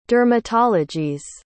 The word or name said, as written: dermatologies